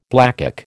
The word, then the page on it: blackcock: American English